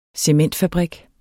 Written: cementfabrik

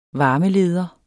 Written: varmeleder

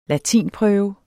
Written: latinprøve

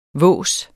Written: våset

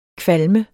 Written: kvalme

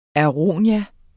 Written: aronia

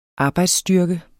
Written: arbejdsstyrkens

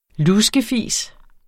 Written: luskefis

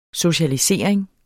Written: socialisering

